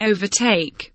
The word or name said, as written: overtake